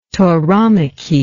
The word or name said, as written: tauromachy